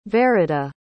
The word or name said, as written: verida